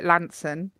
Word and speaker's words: Launceston: Lanson. Now, that's an interesting one, because all the Cornish call it Lanson. But it's actually spelled Launceston, and it's pronounced Launceston. But Everyone from Cornwall calls it Lanson.